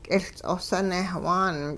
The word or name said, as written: k'iLts'os newhaan